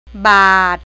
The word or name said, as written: บาท